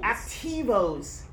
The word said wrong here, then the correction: aperitivo: I'm not pronouncing it right. Aperitivo.